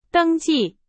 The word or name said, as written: 登记